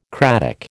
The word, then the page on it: cratic: American English